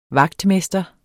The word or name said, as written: vagtmester